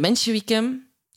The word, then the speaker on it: Mensjewieken: En de Mensjewieken, zeg ik dat goed? Ja, ja. De Mensjewieken.